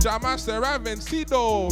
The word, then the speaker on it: Jamás será vencido: Saying it right. Jamás será vencido. Them ones, yeah. El Pueblo Unido. Jamaser Vencido.